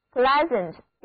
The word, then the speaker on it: pleasant: 那这个令人愉快的单词就是 pleasant, pleasant, 拼写为 P-L-E-A-S-A-N-T, 单词的前半部分是词根 P-L-E-A-S, 后面是形容词后缀 A-N-T, 所以合在一起 pleasant 就是愉快的或者讨人喜欢的意思。那字母组合 EA 还是读作 A。